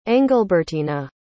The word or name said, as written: engelbertina